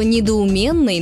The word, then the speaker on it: недоуменный: Например, знаете ли вы, что «недоуменный» нужно говорить только так, через «е», а вовсе не через «ё», как многие любят. «Недоуменный» от слова «недоумение» и на «ё» там не заменяется, как бы некоторым не хотелось это сделать.